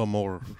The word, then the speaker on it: amor: Of Amor, yeah. – Wait, you gotta roll your R's. – Amor. Yeah.